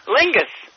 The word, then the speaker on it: Lingus: It's Tom, T-O-M. – Tom. – Right. – Yes. Yeah, and your name is Tom what? – Lingus, L-I-N-G-U-S. – L-I-N? – G-U-S. – G-U-S.